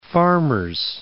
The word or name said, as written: Farmers Branch